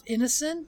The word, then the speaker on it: Ineson: Ralph Ineson? Is that how you pronounce his last name? Yeah.